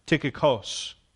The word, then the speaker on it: Tychicus: But Tychicus, it's actually an omicron sigma at the end of his name. So in the English, you see a us sound. I'm doing sounds with Joelle. She's in kindergarten. Short U, right? But actually in the Greek, it's a os, Tychicus, okay?